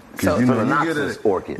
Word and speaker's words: Phalaenopsis: Phalaenopsis orchid.